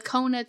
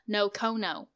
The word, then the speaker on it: Nocona: It's N-O-C-O-N-A. So while I was reading everything, I kept pronouncing it in my head, Nocono. – Nocona? – Yeah. – Or you were putting an O where the A is? – I was putting O's everywhere.